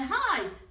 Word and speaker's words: height: But not like height. Pero no como altura. Weight y weight son lo mismo, pero no son como height. Weight, peso y weight, esperar se pronuncia igual, pero no se pronuncia como altura, height. Porque altura, which should always rhyme with might, siempre debería rimar con might.